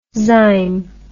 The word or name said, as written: zyme